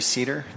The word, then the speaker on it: Seder: My name is Gary Seder. That's spelled S-E-D-E-R.